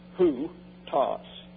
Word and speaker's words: houtos: And then you have, this is considered one letter as a diphthong, treated as if it's one character, but spelled out and pronounced who, toss.